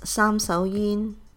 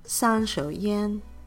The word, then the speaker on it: 三手煙: In Cantonese, it's sam sao yin. In Mandarin, san shou yan.